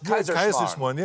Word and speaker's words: Kaiserschmarrn: I didn't know how to pronounce it. Kaiserschmarrn. – Yeah, exactly. With the powdered sugar on top.